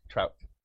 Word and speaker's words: Trout: Trout. – I pronounced it correctly. – Mm-hmm. Just like the fish.